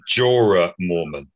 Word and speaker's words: Jorah Mormont: He was Sir Jonah Mormont, also known as... – Mr. Friendzone. – I'm sorry, he was Sir Jorah Mormont.